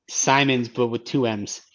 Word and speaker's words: Simons: Simons winning the slam dunk contest. So, and who knows the pronunciation of his last name? – Yes, it's not Simmons.